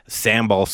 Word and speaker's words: sambal: and Indonesian sambal sauce.